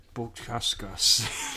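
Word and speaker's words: Bukauskas: I'll go for Buascas. – With the way you pronounce it, you got it, man.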